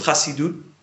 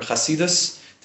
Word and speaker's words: Chassidut: So tonight we're going to learn a piece of Chassidut, or Chassidus, depending on how you pronounce it.